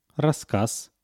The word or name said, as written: rasskaz